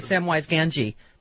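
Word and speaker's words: Samwise Gamgee: Samwise Ganji.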